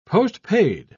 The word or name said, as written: postpaid